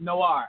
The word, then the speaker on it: noir: How you pronounce noir. – It? Noir. Okay. – Noir. – There we go. I always get confused on that. – It's French, man. It's noir.